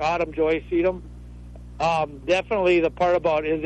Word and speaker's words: sedum: Is it okay to move sedum now or too late already? I think I pronounced that right, sedum? – Yep.